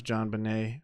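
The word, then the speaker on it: JonBenét: I think it's pronounced JonBenet Ramsey.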